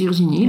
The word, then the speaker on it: Virginie: Now, I know my name is Virginie, like I have no doubt on how it's pronounced.